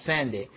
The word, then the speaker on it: Sande: Written by Ken, I think his last name is pronounced Sande. It's S-A-N-D-E.